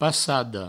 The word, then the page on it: passada: passado